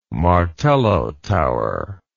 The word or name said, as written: martello tower